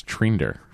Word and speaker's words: Thrinder: But it's spelled Thrinder, but they pronounce it Trinder.